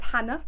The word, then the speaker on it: Hannah: My name is Hannah, H-A-N-N-A-H, and Kashefpour. You might not know how to pronounce that. It's Kashefpour, K-A-S-H-E-F-B-O-U-R.